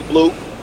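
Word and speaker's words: Bleaux: My friends call me Blue.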